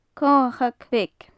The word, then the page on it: comhachag bheag: gaelic